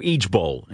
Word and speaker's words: ijbol: There's also Eage Bowl. It's spelled I-J-B-O-L.